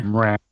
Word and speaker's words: Mraz: I don't know how you pronounce his surname. Go on, you're more hip than me. No? – Mraz.